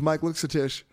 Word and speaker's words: Luksetich: His name is Mike Luksetich.